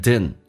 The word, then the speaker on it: didn't: 首先 my 不被强调，用降调来发变成 my，这边有个听力的难点，就是 didn't， didn't 当 d 跟 n 在一起的时候加一点速度，经常老外会把第二个 d 不发音， d 跟 n 在一起 d 不发音就变成 den，就好像 could 说成 couldn't 一样的道理，叫 den， my dad didn't， my dad didn't，所以就变成一个听力的难点。